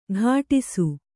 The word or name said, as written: ಕಾಡಿಸು